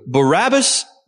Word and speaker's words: Barabbas: So Barabbas' name is actually pronounced Bar-Abbas. It's a combination of two words, Bar meaning son of and Abba meaning the father.